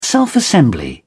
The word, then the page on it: self-assembly: British English